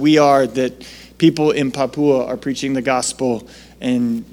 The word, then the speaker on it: Papua: So Papua, that's how they say it, apparently.